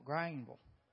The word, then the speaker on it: Greenville: You got to say it right. It's Greenville. G R A I N V U L, Grainville.